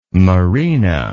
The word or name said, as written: merina